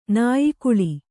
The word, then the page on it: ನಾಯಿಗಳ್ಳಿ: nāyigaḷḷi